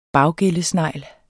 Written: baggællesnegl

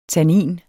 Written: tannin

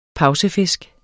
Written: pausefisk

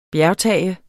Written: bjergtage

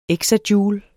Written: exajoule

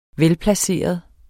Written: velplaceret

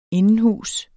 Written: indenhus

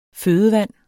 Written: fødevand

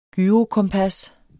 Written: GYROKOMPAS

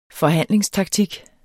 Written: forhandlingstaktik

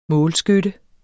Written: målskytte

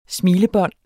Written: smilebånd

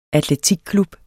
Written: atletikklub

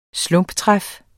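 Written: SLUMPETRÆF

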